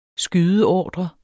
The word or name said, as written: skydeordre